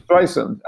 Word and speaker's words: Streisand: Streisand. Actually, that's you pronounce the name. Streisand. – Streisand, yes.